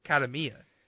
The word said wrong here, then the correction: Kiyomiya: So close. – Oh, my goodness. – Kiyomiya.